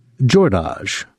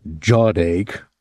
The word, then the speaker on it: Jordache: He liked the way Miss Leneau pronounced his name, correctly. Jordache, making it soft and musical, not Jawdache, as some people said it, or Jordache.